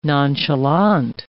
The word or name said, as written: nonchalant